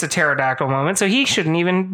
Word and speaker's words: pterodactyl: And so the silent P, which makes no sense, but the silent P in pterodactyl made me say pterodactyl. – Oh, I remember that, yeah. – So I yelled, it's pterodactyl, and then people laughed at me.